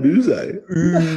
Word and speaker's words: Yze: Number five for me, I've got Adam Uze. – Oh, I thought about him, but I didn't. – Sorry, who? – Adam Uze. – How is that? – His name is spelled YZE as a last name.